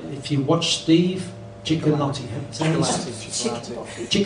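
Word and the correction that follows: Cioccolanti: How do you say his last name? Chocolanti.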